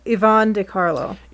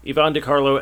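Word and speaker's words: Yvonne De Carlo: I always pronounce her name wrong, so I'm just going to let you do it. – Oh, hang on. Yvonne DiCarlo. – Yvonne DiCarlo as Lily Munster.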